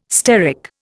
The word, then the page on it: steric: Indian